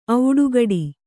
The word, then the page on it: ಒಡೆಗುಟ್ಟು: oḍeguṭṭu